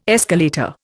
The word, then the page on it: escalator: Indian